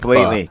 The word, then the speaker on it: pho: It's P-H-O, but it's pronounced pho.